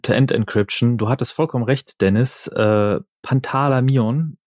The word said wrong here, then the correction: Pantalaimon: Pantalaimon? Wie spricht man das aus? Pantalaimon? Pantalaimon, genau.